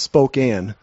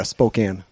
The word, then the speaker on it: Spokane: I've never flown a Boeing, and it's pronounced Spokane. It's kind of like saying Illinois to say Spokane, I think. Yeah, Spokane.